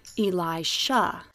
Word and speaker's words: Elisha: Now everyone say, Elisha. Sha, sha, sha, sha. Elisha.